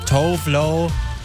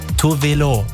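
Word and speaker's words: Tove Lo: Das ist für viele Leute Tovlo, aber eigentlich spricht man sie Tovelo. Ja, das wusste ich auch bis vor kurzem nicht, weil sie kommt ursprünglich aus Schweden und da sagt man das halt richtig so.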